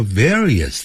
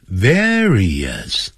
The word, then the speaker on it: various: They fight over various things. Various. 它就一定要念成 various.